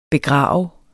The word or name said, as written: begrave